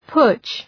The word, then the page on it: putsch: pʋtʃ